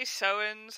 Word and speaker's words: sowens: Yeah, I mean, I always say Sowens. I think it can be said, several different ways. Sowens is usually what I say.